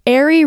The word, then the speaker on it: Ari: So everyone, Ari rhymes with Barry.